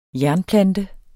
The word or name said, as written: jernplante